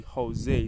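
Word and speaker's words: José: Oh, by the way, some people still pronounce it as Jose, like J-O-S-E, but it is actually Jose. It's pronounced as Jose, you know, like how we have Jose Mourinho.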